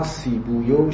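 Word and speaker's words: سیبویه: اصل اینها سیبویو شیرویو اینها اینطور تلفظ میشه چون اصل این زبان ها مال فارسیه، عربی نیست، مرکب مضیه های فارسیه برخلاف بعلبک و حضرموت و اینها که اینها عربیه ولی این الفاظ فارسی.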